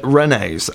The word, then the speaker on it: Rennes: Before we do, though, we have a correction to make because I was mispronouncing it, and I want to make sure to get it right. Renaissance at Rennes. Rennes. You're right.